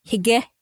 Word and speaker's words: hygge: and we need to practice more hygge.